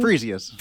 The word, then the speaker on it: Frisius: And so we have Dr. Frisius Mark here with us. Did I say that correctly? Did I say your last name? – Frisius. – Frisius. I'm so sorry. Yes, that's Frisius. Okay.